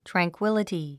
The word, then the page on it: tranquility: træŋkwíləti; トランクィラティ